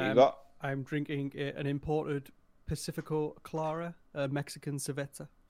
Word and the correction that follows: cerveza: I believe it's pronounced cerveza, but whatever.